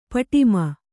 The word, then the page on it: ಪಡಮ: paḍama